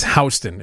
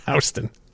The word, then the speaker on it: Houston: You know somebody is not from New York when they ask for directions, like, how do I get to Houston and Third? It's Houston. It's pronounced Houston, which I don't understand.